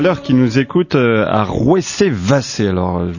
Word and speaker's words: Rouessé-Vassé: Voilà, c'est dans la Sarthe et ça se prononce Rouessé-Vassé. Hein, j'ai dit Rouessé-Vassé tout à l'heure. Je n'avais pas les accents sur mon texte.